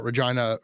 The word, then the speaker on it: Regina: And that's how we found out that it was a family show and he shouldn't do jokes about Regina rhyming with vagina.